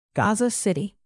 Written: gaza city

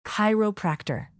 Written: chiropractor

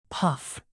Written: puff

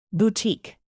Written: boutique

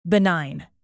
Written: benign

